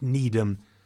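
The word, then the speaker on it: Nedham: But the people who enjoyed themselves the most were Henry Parker, the Observator, Apostle of Liberty, and Marchmont Needham, the Apostle of Gossip and Journalism. Name rhymes with freedom, don't you know?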